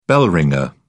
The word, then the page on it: bell ringer: British English